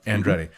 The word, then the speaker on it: Andretti: Mario Andretti. Am I saying that right? – I think that's right. – Okay. – Andretti.